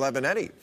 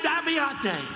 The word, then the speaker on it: Avenatti: and it's great the way he makes Trump so mad he can't pronounce his name right. – Another woman just reported by a sleazebag lawyer named Aviate.